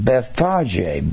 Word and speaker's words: Bethphage: And he was in Bethphage, B E T H P H A G E we would pronounce that pronounce that Beth Page in our day and time.